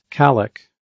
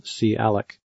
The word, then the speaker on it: calloc: One is calloc, or sometimes pronounced calloc.